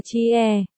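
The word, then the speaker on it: chi e (切): Tiêu ti dịch là thiết có nghĩa là cắt đọc là chi e.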